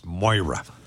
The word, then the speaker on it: Maire: It's spelled Mare, M-A-I-R-E, but apparently it's pronounced Moira.